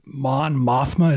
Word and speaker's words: Mon Mothma: So Genevieve O'Reilly, who plays Mon Mothma.